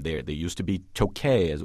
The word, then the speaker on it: Tokaj: It's spelled T-O-K-A-J, by the way, but it is pronounced in Hungarian. I've been there, so I know.